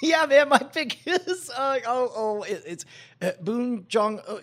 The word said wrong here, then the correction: Bong Joon Ho: How do you say his name? – Bong Joon Ho. – Yes, Bong Joon Ho.